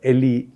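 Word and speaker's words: Leh: The Chinese had come to a place called Le, or Li, Li you can call it, but in Tibetan, I'm told it's pronounced as Le.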